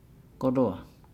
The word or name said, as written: goloa